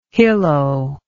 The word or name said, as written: hillo